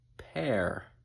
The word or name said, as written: pear